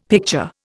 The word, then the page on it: picture: Indian